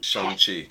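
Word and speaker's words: Shang-Chi: Shang Chi. Shang Chi. – Great pronunciation.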